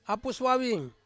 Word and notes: apus wawin: Ansus

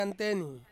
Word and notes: anteni: Ansus

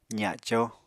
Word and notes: zaco: apatani

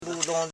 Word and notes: budon: bu.don

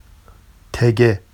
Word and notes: teɟe: Vafsi

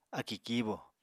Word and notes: akì kíibò: apatani

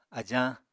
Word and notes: ajañ: Apatani

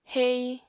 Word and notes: hei: apatani

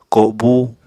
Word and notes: kobu: Apatani

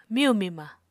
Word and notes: miyu-mima: Apatani

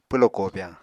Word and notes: pwlo kobyañ: Apatani

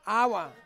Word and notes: awa: Ansus

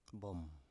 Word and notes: bom: Biak

